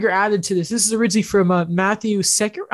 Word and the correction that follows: Sekeres: I can't say his name properly. S-E-K-E-R-E-S. – Matt Sekeres.